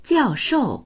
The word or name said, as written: jiao4shou4